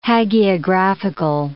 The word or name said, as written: hagiographical